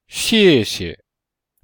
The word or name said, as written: xie4xie5